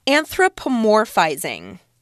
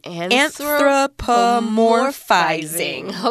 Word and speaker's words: anthropomorphizing: It says, this is called anthropomorphizing. You get that? – Anthropomorphizing. Okay. – Yeah. All right. You can count it out with your syllables there. Anthropomorphizing. Six syllables.